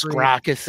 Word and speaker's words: Gracchus: The way that like when is it Gracchus? I want to get his name right. The man who's in like basically. – Yes, Gracchus is Derek Jacobi.